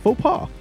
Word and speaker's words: faux pas: You know, after its fox paws. – Yeah, fox paws. – Faux pas. The way it's spelled, fox paws.